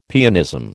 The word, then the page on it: pianism: American English